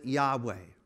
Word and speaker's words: Yahweh: The best known way that people use to pronounce W or YHWH is to put an A and an E in there, two vowels, and they pronounce it Yahweh.